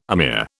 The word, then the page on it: amir: British English